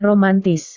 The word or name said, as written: romantis